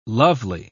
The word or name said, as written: lovely